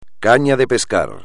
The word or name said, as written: caña de pescar